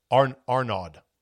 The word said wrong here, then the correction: Ahmaud: Arbery. I should get his name right, lest I get criticized. Type this in. A-R-B-E-R-Y. Oop, botched that one. No, hold on a second. If I only had hold music. Arbery. Yeah, Ahmaud.